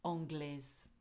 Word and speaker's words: anglaise: So notice the difference again, because you've got a consonant at the end which the E makes pronounced. So anglais and anglaise.